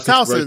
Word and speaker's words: Towson: So later on, you know, you mentioned going to Tucson. Am I saying that right? – Towson.